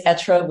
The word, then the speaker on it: être: And the pronunciation is perfect. The word is être, which is the French verb to be. And I should be pronouncing it with so much more French flair, but we are not.